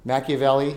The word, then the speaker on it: Machiavelli: Is it Machiavelli? Is that how you say it? – Machiavelli.